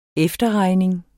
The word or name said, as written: efterregning